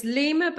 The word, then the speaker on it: Lyma: Well, it's Lima, but it's spelled L-Y-M-A, but it's lima.life, and there's a journal section and you can find out more about the menopause guide through that.